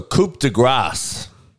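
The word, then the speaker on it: coup de grâce: So coup de grace. You hear that? Coup de grace. Is how it's supposed to be pronounced. Because if you say coup de grace and you don't pronounce the S, you're basically saying stroke of fat instead of stroke of grace.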